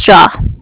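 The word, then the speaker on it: Jia: My name is Jaw. – How do you spell that? – It's J-I-A.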